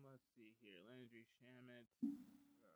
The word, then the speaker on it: Shamet: Mr. Shamet.